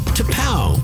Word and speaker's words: T'Pau: T-apostrophe-P-A-U, pronounced T-P-A-U.